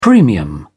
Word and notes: premium: American English

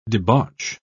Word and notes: debauch: British English

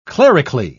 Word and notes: clerically: British English

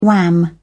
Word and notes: wham: British English

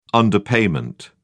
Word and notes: underpayment: British English

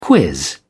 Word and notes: quiz: British English

